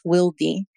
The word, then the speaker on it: Wildy: It's actually pronounced Wildy because it's Spanish.